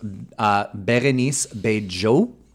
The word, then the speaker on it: Bérénice Bejo: Let's talk about Bérénice Bejo.